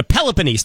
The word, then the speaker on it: Peloponnese: The Peloponnese. Did I pronounce it right? – You finally got it right. – third try is a charm.